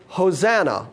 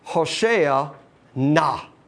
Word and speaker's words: Hosanna: The accent is on the last syllable. It's Hosea na.